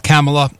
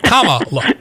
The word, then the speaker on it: Kamala: Now that we know how to pronounce her name, it's not Kamala, it's not Kamala, it's Kamala.